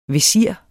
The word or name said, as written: vesir